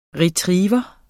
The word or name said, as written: Retriever